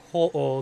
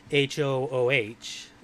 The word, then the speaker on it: Ho-Oh: Because in the chat there, Ho-Oh, the Pokemon, is spelled H-O-O-H, whereas the spelling of the actual yokai itself is just Ho-Oh, without the H. – With a long O.